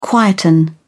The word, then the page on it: quieten: British English